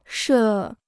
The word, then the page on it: 设: shè